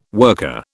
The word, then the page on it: worker: British English